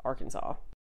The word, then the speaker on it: Arkansas: Um, but they also did not pronounce the S. So that is how Arkansas came to be pronounced Arkansas.